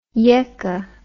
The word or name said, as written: yekke